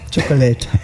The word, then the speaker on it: Chocolate: Rhythmus de la Noche von Schokolade. – Ja, Chocolate. – Ach so, so wird das ausgesprochen.